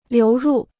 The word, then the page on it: 流入: liú rù